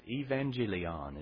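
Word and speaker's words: evangelion (εὐαγγέλιον): It actually is pronounced evangelion is the way the word's pronounced.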